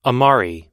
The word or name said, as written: amari